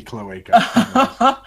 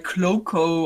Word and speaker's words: cloaca: Hey, a clean cloaca is a happy cloaca. – Oh. Oh, that's how you pronounce it in English. Okay, I always thought it was like cloaco or so, cloaca.